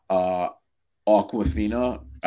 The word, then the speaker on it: Awkwafina: By the way, what I also really liked about that movie was Awkwafina. I don't know if I'm pronouncing it right. – Yeah, you're saying her name right.